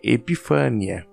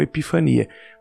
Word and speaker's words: Epifânia: Então, eu aprendi, quando eu aprendi é, o nome da festa, eu aprendi como Epifania mas em grego a pronúncia mais aproximada é Epifânia, tá? E não Epifania.